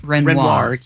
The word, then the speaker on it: Renoir: Renoir.